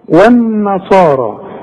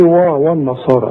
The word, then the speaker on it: والنصارى: خلاص والذين هادوا خدتها انت دلوقتي، هادوا كلها مرققة، والنصارى يا ريتك تنطقها زي ما انا نطقتها، انما انت هتقول لي والنصارى والنا، شوف و والنا غلط، ده انت لسه قايل دلوقتي والذين، الواو دائما مرققة، هنا ليه فخمتها لي قلت لي و والنصارى؟